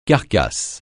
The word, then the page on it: carcasse: francais